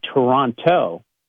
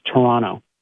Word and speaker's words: Toronto: Americans would say Toronto, but did you know that you don't pronounce the second T if you're, you know, a local? Just like we don't say Lancaster, we say Lancaster, so it's Toronto.